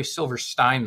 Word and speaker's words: Silverstein: Some people call me Corey Silverstein, though, like Frankenstein. – Okay. – So, you know, just in case you wanted to, like, you know, pronounce my name correctly.